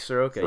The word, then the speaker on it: Soroka: Like not to just name Braves, like Soroka. Is that how he's pronounced? – Mike Soroka, yeah.